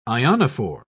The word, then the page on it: ionophore: British English